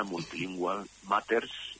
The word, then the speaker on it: Multilingual Matters: Está publicado con Multilingual Matters, lo voy a decir la pronunciación castellanizada, Multilingual Matters, que está disponible online.